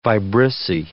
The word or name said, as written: vibrissae